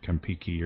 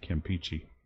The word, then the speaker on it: Campeche: Mark's ancestors in the area pronounce it Kampiki or something like that. And I have not been able to find a single reference to that. – Eh, whatever it is. – But if you actually look down there at the writing on this is an English-made map, and they've spelled it Campechy, so it's clear that they pronounced it Campeche.